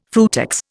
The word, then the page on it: frutex: Indian